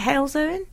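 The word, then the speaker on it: Halesowen: You were the reason why I left Hales Owen. Is it Hales Owen? – Hales Owen, yes.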